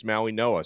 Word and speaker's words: Mauigoa: Apparently, that's the Hawaiian, the Pacific Islander pronunciation is Maui Noa.